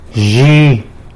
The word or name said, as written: yzhi